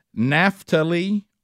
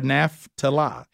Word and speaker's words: Naphtali: This is how you say it. It's Naphtali or Naphtali, either one of those works.